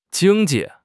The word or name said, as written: jing1 ji4